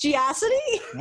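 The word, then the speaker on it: GeoCities: Wait, is it supposed to be pronounced Geocity? – We pronounced it Geocity because it rhymed with atrocity.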